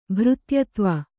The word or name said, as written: ಭೃತ್ಯತ್ವ